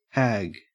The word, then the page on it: hag: Australian English